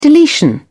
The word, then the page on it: deletion: British English